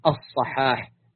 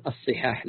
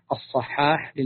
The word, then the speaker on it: الصحاح: وأيضا من مصادره الصحاح للجوهري، هكذا تنطق بفتح الصاد، الصحاح للجوهري، ويخطئ كثير ويقول الصحاح، لا هو ذكرها بالفتح الصحاح للجوهري، ولذلك اختصاره كثير من الإخوة يقول مختار الصحاح لأبي بكر الرازي، وهذا غير صحيح، هي مختار الصحاح لأبي بكر الرازي.